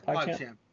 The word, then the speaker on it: PogChamp: PogChamp? – Yeah, PogChamp.